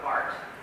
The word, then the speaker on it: Barth: One of the big ones was a guy by the name of Karl Barth, or spelled like Barth, but Barth.